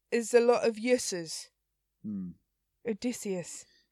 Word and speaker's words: Odysseus: Is it Odysseus? – Odysseus.